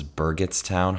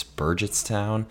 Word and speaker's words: Burgettstown: I was pronouncing the town at Star Lake wrong. I thought it was Burgettstown, hard G. It's Burgettstown.